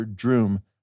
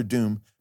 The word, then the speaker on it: Droom: But before he was Dr. Druid, he was Dr. Droom. Not Dr. Doom, but Dr. Droom with an R, rhyming with broom.